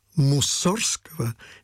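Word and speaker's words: Мусоргского: Очень хотелось бы услышать замечательную жанровую сценку Муссорского. Я произношу эту фамилию так, как ее произносил, как мне известно, Модест Петрович и ведущие советские дирижеры 20 века. Не от Мусора же фамилия, в конце концов.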